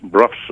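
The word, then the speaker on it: Brough: Now, the Bro Superior, did I pronounce that right? Did I say Bro? – Bruff Superior, right. Like rough and enough and tough.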